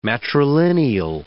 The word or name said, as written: matrilineal